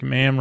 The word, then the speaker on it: ma'am: Something like Ma'am rhymes with ham. Not ma'am, rhyme, mar, mom, farm.